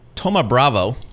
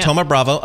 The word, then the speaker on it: Thoma Bravo: A stock that you cover, Coupa, going to be acquired by Thoma Bravo. Is that how I pronounce that correctly? – Yeah. – Thoma Bravo.